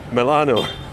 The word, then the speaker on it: Milano: You didn't say it right yet. One more go. We are Milano. You can do it. – Milano.